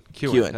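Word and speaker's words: Keown: I think it almost sounds like a Kewen. Kewen. That's right.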